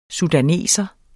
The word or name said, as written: sudaner